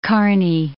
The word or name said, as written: Kearney